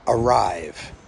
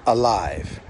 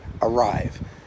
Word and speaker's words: arrive: And she reminded me that I helped her say the word arrive. Not alive, arrive.